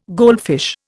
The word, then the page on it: goldfish: Indian